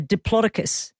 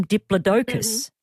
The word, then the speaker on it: Diplodocus: One of those dinosaurs, when I was growing up, it was called a Diplodocus, but now they call them Diplodocus. – Mm-hmm. Yeah, I've saw that name, yes. – Oh, it's just strange because the emphasis is on the wrong syllable for me there, because I've always known it as a Diplodocus.